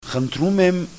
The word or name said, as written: khntrumem